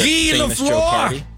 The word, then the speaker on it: Guy Lafleur: The Guy Lafleur! – Guy Lafleur. – Guy Lafleur. – Guy Lafleur. It's spelled Guy. It's so funny from our perspective.